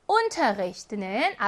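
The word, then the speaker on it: Unterricht: Unterricht는 앞에 있는 우에 강세가 있습니다. 자, 요거는 주의하셔야 되는데요. 수업을 하다 라고 하는 Unterrichten 이라고 하는 동사는 비분리 동사입니다. 그래서 요 동사의 강세는 Unterrichten 여기 있어요. 즉 삼음절의 강세가 있어요. 그런데 요 아이를 명사로 만들어서 수업이라는 명사를 만들면 강세가 일음절로 이동해요.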